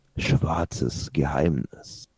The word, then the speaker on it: Schwarzes Geheimnis: And this beer actually has a subtitle, Schwarzes Geheimnis. Or let me say it like that, Schwarzes Geheimnis.